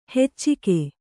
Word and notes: ಹೆಚ್ಚಿಗೆ: heccige; kannada

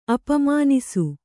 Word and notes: ಅಪಮಾನಿಸು: Kannada

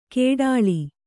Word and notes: ಕೇಡಾಳಿ: kēḍāḷi

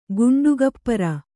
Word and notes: ಘಂಟಾಗೋಪುರ: kannada

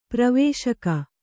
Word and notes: ಪ್ರವೇಶಕ: pravēśaka

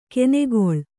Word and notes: ಕೆನೆಗೊಳ್: kenegoḷ

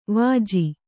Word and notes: ವೆಜ್ಜ: vejja

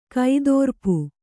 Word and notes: ಕೈದೋರ್ಪು: Kannada

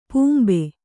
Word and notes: ಪಾಂಬ: pāmba

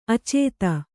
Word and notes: ಆಚದು: kannada

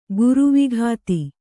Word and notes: ಗರ್ವೋಕ್ತಿ: Kannada

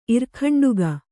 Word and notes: ಇರ್ಖಂಡುಗ: irkhaṇḍuga

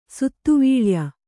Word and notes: ಸುತ್ತುವಳಯ: suttu vaḷaya

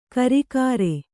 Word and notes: ಕರಿಕಾರೆ: Kannada

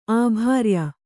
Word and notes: ಅಭ್ರಿಯ: abhriya

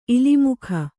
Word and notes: ಇಲಿಮುಖ: ilimukha

